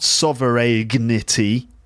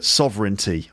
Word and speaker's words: sovereignty: Don't ask me why there's a G in that word. It's not pronounced, so it's actually sovereignty, not sovereignty, but sovereignty.